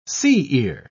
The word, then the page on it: sea-ear: British English